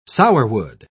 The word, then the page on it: sourwood: British English